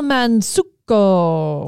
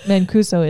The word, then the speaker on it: Mancuso: Is that how you say it? Mansuko? – That's not it. I changed it. – Mancuso? – I think Mansuko sounds better. – It does actually sound better. – Mancuso is her name, though.